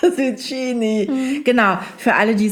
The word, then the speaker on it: Zucchini: CCH spricht man immer als K, denn das H ist dafür da, um aus dem Tsch ein K zu machen. – Deswegen heißt es Cappuccino mit zwei C, aber Zucchini.